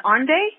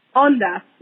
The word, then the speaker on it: Onda: Onde? – Onda.